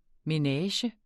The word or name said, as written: menage